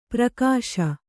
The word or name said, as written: ಪರಿಘೋಷ